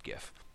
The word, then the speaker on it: GIF: If you like comic art panels, if you like GIFs, who doesn't like a good GIF? By the way, it's pronounced GIF.